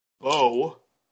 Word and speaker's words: Bow: In 2016, myself, Scott, who was on last week, and our friend Andy, we stayed at Andy's friend's flat in Bow. I was told that's how you pronounce it.